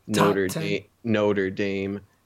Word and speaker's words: Notre Dame: No, losing to Notre Dame. It rhymes with in the rain.